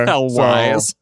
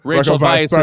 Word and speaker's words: Rachel Weisz: Raquel Wise. Rachel Weisz is the English Rachel pronunciation.